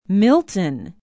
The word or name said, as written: milton